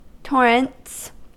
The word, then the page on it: torrents: American English